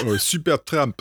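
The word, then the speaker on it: Supertramp: Alors, nous allons faire une pause de musique avec Subbertramp. Alors, je prononce très mal. Peut-être que vous pouvez reprononcer pour moi. – Subbertramp. Donc, voilà.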